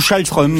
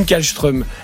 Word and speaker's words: Kallström: Suivant les médias où vous écoutiez les matchs, c'était où Kallström ou Schallström. – Voilà. Voilà. Bah, okay. Et moi, je disais toujours, alors, si on commence à dire Schallström, il faut qu'on dise tous les noms dans leur vraie prononciation de tous les pays. – Et moi, je disais c'est Schallström, parce que tu n'arriveras jamais à le dire correctement.